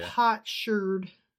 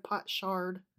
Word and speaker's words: potsherd: There's the pronunciation for you. – Potsherd. – Potsherd. – Instead of pot shard.